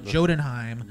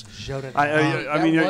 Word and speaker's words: Jotunheim: that story is going to be about if Odin had lost to the king of Jotunheim. – Yeah, I'm sorry, I pronounced the word the way it looks. – Jotunheim.